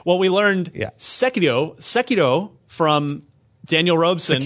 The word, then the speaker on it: Sekiro: Dan- Daniel Robson, the editor in chief of IGN Japan, tweeted us and said, "Oh, it's emphasized the first syllable. – That was that's the Hiro. like that. – Hiro. Sekiro.